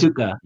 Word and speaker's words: Dukkha: So, you know, there's this is in reference to, I'm probably going to murder this pronunciation, but Sankara Dukkha. And that is Dukkha. Yeah. – Dukkha.